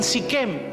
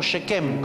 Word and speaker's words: Shechem: Porque Dios le manda a construir ahora una segunda ciudad de refugio en Siquem o Shechem, como sería mejor pronunciado.